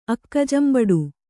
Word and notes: ಅಕ್ಕಜಂಬಡು: kannada

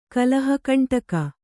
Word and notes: ಕಲಹಕಂಟಕ: kalahakaṇṭaka

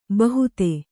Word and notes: ಬಹುತೆ: kannada

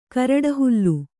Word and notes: ಕರಡಹುಲ್ಲು: karaḍahullu

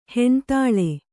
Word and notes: ಹೆಣ್ತಾಳೆ: kannada

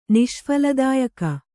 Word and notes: ನಿಷ್ಫಲದಾಯಕ: niṣphaladāyaka

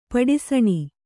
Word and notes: ಪಡಿಸಣ: paḍisaṇa; Kannada

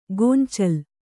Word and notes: ಗೊಂಚಲ್: kannada

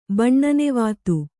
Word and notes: ಬಣ್ಣನೆವಾತು: Kannada

